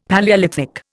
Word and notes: Palaeolithic: Indian

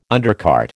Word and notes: undercart: American English

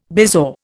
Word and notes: bezoar: Indian

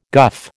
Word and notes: guff: American English

